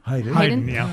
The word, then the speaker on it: Haydn: Is it Haydn? – Haydn.